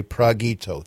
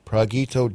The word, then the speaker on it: Pragito: Okay, Pragito. Thank you. Pragito Dove.